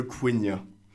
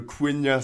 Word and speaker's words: Quenya: Et ce que j'ai essayé de déchiffrer, c'est le Quenya. – Attends, pas le pays. – Non, Martin, c'est le Quenya, ça s'écrit Q-U-E-N-Y-A, et c'est la langue parlée par les elfes Noldor, donc l'une des six langues inventées par Tolkien.